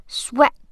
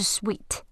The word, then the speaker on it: sweat: 好，这句话很好听，难在 dripping off me Dripping of me， sweat sweat，注意它的语音是 a， sweat 不是 sweet，是 sweat，这里是汗，它不是甜。